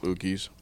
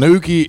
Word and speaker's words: uke: Don't zone out, Ookies.